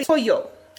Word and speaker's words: pollo: Well, basically, like in the word chicken, it's spelled P-O-L-L-O, and here it would be pollo. Whereas in Argentina, the double L makes a sh sound. So in Argentina, it's pollo.